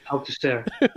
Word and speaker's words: Althusser: but is it Althusser? Is that how you say it? – Althusser.